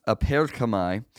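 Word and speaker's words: aperkamai (ἀπέρχομαι): That is the Greek for apokomai. And you can even hear how it's pronounced by clicking this right here. And see, I didn't say it anywhere close. It's aperkamai. So anyway, let's go back.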